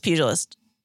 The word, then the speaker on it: pugilist: I'm pretty sure it's a soft G. Okay. It's pugilist.